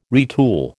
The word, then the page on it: retool: American English